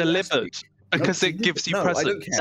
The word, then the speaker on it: Delibird: Delibird is everyone has been mispronouncing it, and it's Delibird.